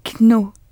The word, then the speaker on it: know: K-N-O-W. – Eh oui, mais on ne prononce pas le K. C'est la même prononciation que pour no.